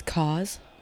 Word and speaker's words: Kaz: It's Kaz. – Like, can you spell that? – K-A-Z. – Oh, it's a K. Uh-huh. – Pronounced like cause and effect.